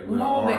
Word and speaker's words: Marbury: How do you say Mulberry? Mulberry. Am I saying it yet? – You're putting L's that don't exist in that word.